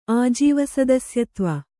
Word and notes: ಆಜೀವಸದಸ್ಯತ್ವ: Kannada

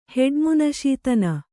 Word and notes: ಹೆಡ್ಮುನಶೀತನ: kannada

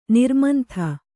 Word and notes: ನಿರ್ಮಂಥ: nirmantha

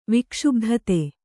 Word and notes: ವಿಕ್ಷುಬ್ಧತೆ: vikṣubdhate

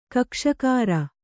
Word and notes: ಕಕ್ಕಸಗಾರ: kakkasagāra

